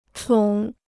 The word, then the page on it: 宠: chǒng